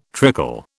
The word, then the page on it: trickle: British English